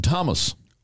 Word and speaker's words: Thomas: For example, you don't pronounce the H in Thomas. – Or Thames.